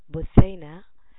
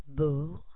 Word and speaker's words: Busaina: Please note that my name, Busaina, has three syllables. Busaina. And I say it again, as pronounced like said as Busaina. Busaina.